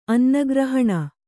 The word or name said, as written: ಅನ್ನಗ್ರಹಣ